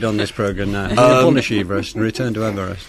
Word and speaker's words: Everest: Its leaders included George Everest, who I understand is now to be pronounced George Everest, after whom the world's highest peak is named.